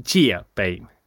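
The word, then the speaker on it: Jiebei (借呗): Ja, absolut. Huobi och Jiebei uttalar man dem.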